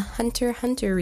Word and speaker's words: Hunter x Hunter: So, for those of you who don't know, Hunter Hunter or Hunter X Hunter were you special few who uh, like pronounce the X, um, even though you're not supposed to. It's uh, Hunter Hunter actually had an original series that came out back in like the 80s.